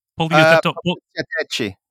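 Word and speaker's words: poliziotteschi: How do you say that? – Poliziotecci. I think you said Poliziotecci.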